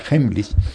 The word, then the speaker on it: Heimlich: Se escribe H-E-I, pero se pronuncia como una J, Heimlich.